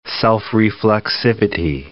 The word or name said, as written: self-reflexivity